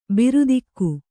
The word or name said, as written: ಭೃತಕ